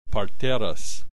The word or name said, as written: parteras